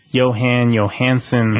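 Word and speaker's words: Jóhann Jóhannsson: Composer who is now dead, rest in peace, Johan Johansson. – Mm-hmm. – I think I'm saying that right. – That's the way I've heard it. Yeah, I think.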